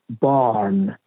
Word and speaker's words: barn: مثلا در کلمه B A R N تلفظش هست بارن در واقع ره خونده نمیشه به دلیل اینکه درست قبلش یه مصوت هست A ولی بعد از اون N هست یعنی مصوت نیست بنابراین میتونه خونده نشه